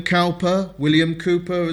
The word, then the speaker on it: Cowper: There is a tremendous hymn by William Cowper, as some people say. I think that's the right way to say it.